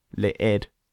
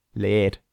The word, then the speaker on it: leer: Leer. Leer. – No, leer. – Okay, leer, which means to read, is spelt L-E-E-R. So it looks like in English, leer. Don't pronounce it like that. It's leer.